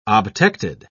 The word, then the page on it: obtected: British English